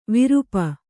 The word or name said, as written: ವಿರುಪ